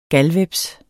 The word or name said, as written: galhveps